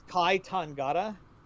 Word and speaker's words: Kai Tangata: is Kai Tangata.